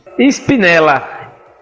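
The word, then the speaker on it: Spinella: Espinela. S mudo P-I-N-E-L-L-A. Espinela Oficial. Lingerie de luxo.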